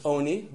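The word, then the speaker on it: Oni (עני): And it's spelled, Oni is spelled like Ani. It's pronounced Oni in the Torah, but it's spelled Ani, Lechem Ani.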